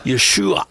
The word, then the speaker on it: Yeshua: which is called ayin. And we don't have that sound in English. – No. – It's an a sound. It doesn't exist for us. – Yeah. – So the proper pronunciation would be Yeshua. But again, most Americans can't make that last sound. They can do the SH, not the ayin.